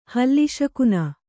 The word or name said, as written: ಹಲ್ಲಿಸಕುನ